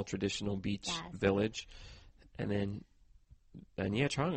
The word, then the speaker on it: Nha Trang: Am I saying that right? Nha Trang? – Nha Trang.